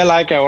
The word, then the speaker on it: Leica: So you shoot everything with your Leica, right? Did I say it right? – Yeah, Leica or Hasselblad.